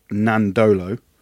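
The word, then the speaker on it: Nadolo: The Fijian way is all silent N, so Nandolo.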